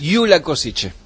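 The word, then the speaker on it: Gyula Kosice: Yula Kosice. – Eso, así se dice.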